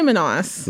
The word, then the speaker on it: Manas: We say Manas.